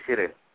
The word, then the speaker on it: Cyril: My first name is Cyril.